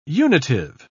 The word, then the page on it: unitive: British English